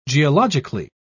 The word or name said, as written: geologically